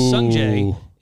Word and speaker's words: Sungjae: That is Sungjae and Byung-hoon On, I believe, right? Mm-hmm. I think is how you pronounce that. So my apologies if I butchered that. – I think that. – You absolutely nailed it.